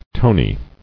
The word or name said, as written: Tonies